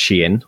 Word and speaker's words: Shein: So brands like Shein, which you might have seen spelt Shine, but it is apparently pronounced Shein, or so I learned for a recent video.